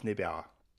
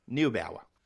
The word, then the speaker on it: Neubauer: And the 35 with Timo Glock, Martin Chomchik, and Thomas Neubauer. I've mispronounced that last name so badly. – Neubauer.